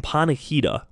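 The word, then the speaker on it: Panikhida: And Panahida, it's spelled Panachida.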